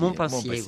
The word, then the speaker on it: Montpensier: Nous, je le prononce bien, Montpensier. – Oui, Montpensier, oui.